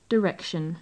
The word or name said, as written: direction